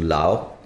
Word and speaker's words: Laos: So, and then we crossed over to Lao. – Okay. – Lao People's Democratic Republic. Lao PDR, not really known. – S is silent, right? – Aap log Laos Laos bolte hain.